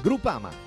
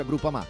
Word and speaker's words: Groupama: Groupama. Ah no, si pronuncia Groupama.